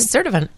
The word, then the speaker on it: Sturtevant: Sherry from Sturtevant? – Is that Wisconsin. – Is that the right way to pronounce it? – Sturtevant, yeah.